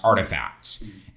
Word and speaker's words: RTFKT: And so Nike, I think it was December, they acquired a company that's spelled RTFKT, but is pronounced Artifacts.